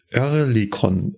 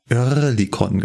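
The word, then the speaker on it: Oerlikon: Ich habe mir den Begriff Örlikon, mal gucken, ob ich das besser aussprechen kann, Örlikon genommen.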